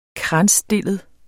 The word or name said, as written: kransstillet